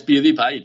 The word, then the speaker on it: PewDiePie: por, decir, por decir uno, ¿cómo se pronuncia bien? ¿Cómo es? ¿PewDiePie o PewDiePie? es PewDiePie, tío. – PewDiePie, Vale, puede ser, porque American Pie se escribe igual y se dice American Pie toda la vida.